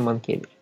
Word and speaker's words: Манкевич: Да, Манкевич у него на Е ударение. Правильно, Манкевич.